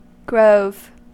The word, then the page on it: grove: American English